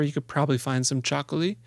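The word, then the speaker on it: txakoli: It's called Chacoli. It's spelled funny. It's T-X-A-K-O-L-I, I think, something like that.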